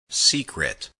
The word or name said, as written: secret